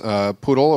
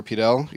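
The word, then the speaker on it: PDAL: Poodle or Pidel, either pronunciation is fine, and Entwine.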